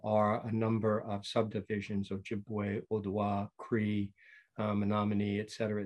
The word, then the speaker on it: Ojibwe: And I had to find out how to pronounce this. So it's a part Irish and part, don't say it, Ojibwe, right? – You're pretty good. Ojibwe.